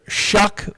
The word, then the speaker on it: Shough: They're going to get Tyler Shuck, which is spelled S-H-O-U-G-H. No rule in the English language suggests that should be Shuck, but.